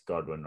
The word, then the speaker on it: Godwin: Is it Godwin? It's not Goodwin. It's Godwin, right? – Chris Godwin.